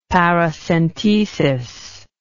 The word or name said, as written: paracentesis